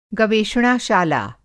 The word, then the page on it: गवेषणाशाला: Hindi